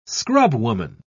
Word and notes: scrubwoman: British English

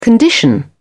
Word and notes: condition: British English

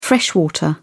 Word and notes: freshwater: British English

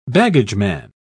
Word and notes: baggageman: British English

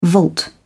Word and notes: vault: British English

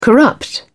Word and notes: corrupt: British English

